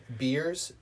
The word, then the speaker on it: Beers: Timothy, T-I-M-O-T-H-Y, Beers, B-E-E-R-S.